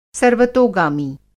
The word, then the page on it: सर्वतोगामी: Hindi